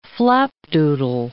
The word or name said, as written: flapdoodle